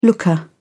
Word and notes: looker: British English

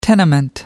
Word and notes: tenement: American English

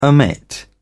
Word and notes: omit: British English

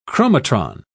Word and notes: chromatron: British English

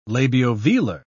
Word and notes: labiovelar: British English

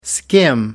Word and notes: skim: American English

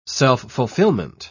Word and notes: self-fulfillment: British English

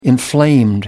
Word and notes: inflamed: American English

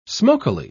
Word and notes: smokily: British English

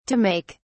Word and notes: to make: British English

